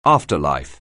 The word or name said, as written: afterlife